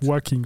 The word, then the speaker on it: Joaquin: Ich hätte gesagt Joachim Phoenix. – Nein, das also glaube ich nicht. Joaquin.